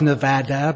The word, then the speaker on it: Nevada: but I didn't realize it was pronounced Nevada, but I'm glad you gave me a heads up and I won't be killed for saying it the wrong way.